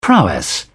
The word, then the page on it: prowess: British English